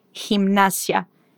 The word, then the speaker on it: gimnasia: E atenção com a pronúncia de ginástica em espanhol. Dizemos gimnasia. Gimnasia.